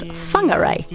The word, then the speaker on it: Whangarei: and we went up to Whangarei, which is Maori pronunciation is Whangarei.